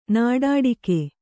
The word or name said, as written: ನಾಡಾಡಿಗ